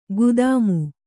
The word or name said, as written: ಖದೀಮ